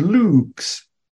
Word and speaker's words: lux: N-U-N-C. Nunc. Long U is like the U in boot. And I gave you the word lux.